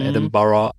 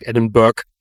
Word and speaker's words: Edinburgh: wobei es da ja auch spannend ist mit der Aussprache, die Stadt hat ja 1000 Namen, Edinburgh, oder wie der Deutsche sagt, Edinburgh. – Ja, richtig, genau. – Das ist aber falsch.